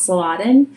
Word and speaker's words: Saladin: So we read this really great book on, I think his name is pronounced Saladin, but he was the ruler in, like, Jerusalem at that time.